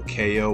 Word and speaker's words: Kayo: So KO, which is spelled K-A-Y-O, right?